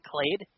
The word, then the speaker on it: Clade: Is it Clade? – Yeah, Clade, yep. – It is just Clade, okay.